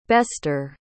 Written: bester

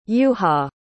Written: juha

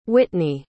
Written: whitney

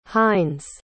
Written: heinz

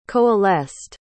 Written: coalesced

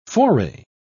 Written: foray

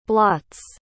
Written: blots

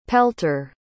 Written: pelter

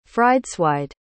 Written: frideswide

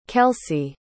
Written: kelcie

